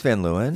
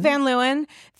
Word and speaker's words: Van Leeuwen: I am terribly upset I wasn't there, but I did get invited to Van, I don't know how to pronounce it, Van Leeuwen. – The ice cream? – The ice cream place. – I think it's Van Leeuwen. – Van Leeuwen.